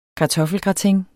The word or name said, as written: kartoffelgratin